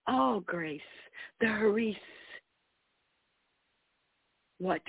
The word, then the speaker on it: Charis: Charis. It's spelled C-H-A-R-I-S.